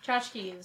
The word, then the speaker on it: tchotchke: Tchotchke is pronounced tchotchke.